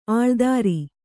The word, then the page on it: ಅಳ್ದರಿ: kannada